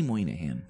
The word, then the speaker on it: Moynihan: Yes, I never know how to pronounce his last name. So it's Bobby Moynihan.